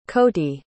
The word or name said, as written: coty